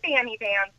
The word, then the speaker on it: Banny Bands: How do you say it? – Yeah, hi. Actually, it is. That's a funny backstory. – I want to hear it. – Okay, well, we go by Banny Bands now because of the way that I spelled it.